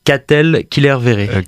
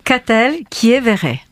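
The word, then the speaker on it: Katell Quillévéré: Répétez-moi ce nom. Comment je dois le prononcer? – Ah, pardon. Katell Killerveré. – Qui est Véré, je crois. – Donc, Katell Killerveré. – Qui est Véré, très bien.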